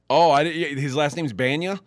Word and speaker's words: Bana: And the guy was Eric Bana.